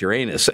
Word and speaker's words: Uranus: Well, technically you're allowed to pronounce it either way, Uranus or Uranus, and I actually chose Uranus in that sentence because I knew that Uranus was an inevitable diversion.